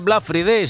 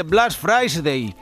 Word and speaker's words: Black Friday: Que no se dice Black Friday, se dice Black Friday.